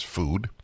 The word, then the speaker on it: FUDE: The FUDE, or food, F-U-D-E, but pronounced as food. It's got an umlaut, two dots over the U.